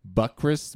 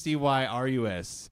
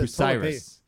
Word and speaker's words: Bucyrus: Buckris, but I don't think that's how you say that. – I think you butchered that one. – Bucyrus. – That's a Busiris. Yeah. – Busiris. It's not Buckris.